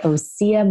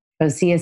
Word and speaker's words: Osea: Today's episode is sponsored by Osea Malibu, the original plant-based results-driven skincare line. Osea, pronounced O-C-E-A,